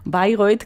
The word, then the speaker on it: Bayreuth: Bueno, no me sorprende Bayreuth, que lo pronunciamos a la alemana,